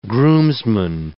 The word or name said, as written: groomsman